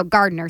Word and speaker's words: Gardner: Like with a T? – No, Gardner. Sorry, just pronounced that word.